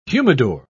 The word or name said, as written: humidor